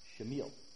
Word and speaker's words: Samuel: And in the Hebrew, it's actually pronounced Shamil. So next time you see Samuel, just call him Shamil.